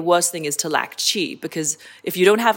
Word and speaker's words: qi: So qi can be qi or chi, depending on how you want to pronounce it.